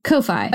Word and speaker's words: Ko-fi: Ko-Fi. It's spelled Ko-Fi, but I guess it's supposed to be coffee.